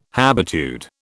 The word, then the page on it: habitude: British English